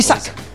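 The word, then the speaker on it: Isak: Isaac.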